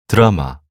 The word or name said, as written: 드라마